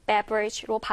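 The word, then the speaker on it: beverage: 강세가 앞부분에 있기 때문에 beverage로 발음된다라는 거 참고로 알면 좋겠습니다.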